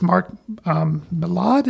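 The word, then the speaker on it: Mark Millar: I don't know if it's Mark Millad. Is that how you say his name? – Yes.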